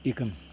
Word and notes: ikim: Yokoim

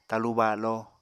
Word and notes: talu balo: apatani

